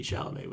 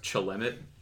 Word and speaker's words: Chalamet: We have Timothy Chalamet with us. – It's actually pronounced Chalamet.